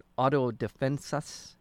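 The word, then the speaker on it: autodefensas: And he is the one who started this this citizens' defense organization, Auto Defensas. Am I saying that correctly? – Mm-hmm. Yeah. Yep.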